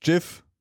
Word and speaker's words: GIF: Und wie wir auch alle wissen, it's pronounced GIF.